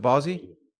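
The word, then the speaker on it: Bosie: But before we get to that, I want to get your Bozzi book. Is it, how do you pronounce it? Is it Bozzi? – Bozzi.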